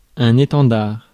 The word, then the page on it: étendard: France; [e.tɑ̃.daʁ]